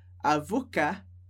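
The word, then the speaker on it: avocat: O masculino, sem o E, a gente não pronuncia o T, então a gente fala avocar, avocar, e feminino, com o E a gente pronuncia o T que vem antes, mas não pronuncia o E.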